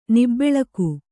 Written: ನಿಬ್ಬೆಳಕು

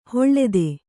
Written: ಹಳತು

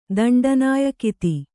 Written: ದಂಡನಾಯಕಿತಿ

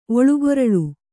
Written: ಒಳುಗೊರಳು

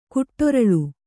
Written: ಕಾಡರಳೆ

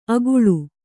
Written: ಅಗುಳು

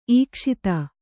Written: ಈಕ್ಷಿತ

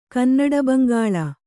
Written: ಕನ್ನಡಬಂಗಾಳ

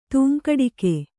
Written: ತೂಂಕಡಿಕೆ

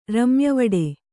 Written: ರಮ್ಯವಡೆ

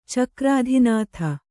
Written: ಚಕ್ರಾಧಿನಾಥ

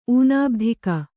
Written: ಊನಾಬ್ಧಿಕ

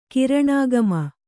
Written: ಕಾರಣಾಗಮ

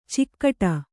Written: ಚೌಗಡ

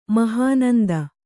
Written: ಮಹಾನಂದ

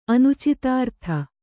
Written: ಅನುಚಿತಾರ್ಥ

